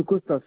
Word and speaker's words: Costa: Monsieur Costa, COSTA.